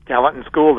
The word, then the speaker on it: Gallatin: And he is responsible for creating the Gallatin. Did I say that right? – Gallatin School, that's correct.